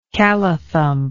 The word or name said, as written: callathump